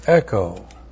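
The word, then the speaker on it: echo (ἔχω): It's used to introduce a third class condition and therefore is correctly translated, but if anyone, and then the verb echo, E-C-H, long O, echo.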